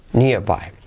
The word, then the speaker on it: nearby: Nearby. Hey, is there any ATM nearby? Nearby, y esa R es como Ahí suaviza nearby, ¿vale?